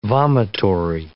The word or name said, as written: vomitory